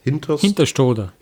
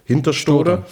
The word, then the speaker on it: Hinterstoder: Und dann gibt es in Hinterstoder, wie spricht man es aus? – Hinterstoder. – Hinterstoder.